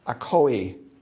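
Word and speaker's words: Akoi (ἀκοή): Akoi, it's a long E.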